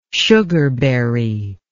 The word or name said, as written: sugarberry